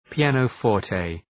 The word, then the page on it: pianoforte: pi:,ænə’fɔ:rtı